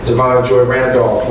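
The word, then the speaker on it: Da'Vine Joy Randolph: But this year, you know, we had Coleman Domingo, we had Danielle Brooks, we had Fantasia, we had Devon Joy Randolph, we had, did I say her name right? – Yeah.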